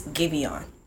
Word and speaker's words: Giveon: It's Giveon.